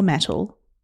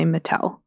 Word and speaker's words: Mattel: Is it pronounced Mattel or Mattel? That's what I want to know first. – I think it depends on your accent, but for the sake of us, please, please let's just say Mattel.